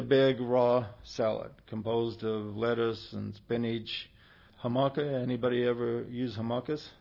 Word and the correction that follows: jicama: Oh, okay, jicama.